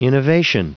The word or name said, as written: innovation